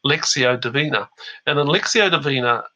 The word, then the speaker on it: Lectio: Lectio Divina, but it's called pronounced Lectio.